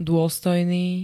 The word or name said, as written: dôstojný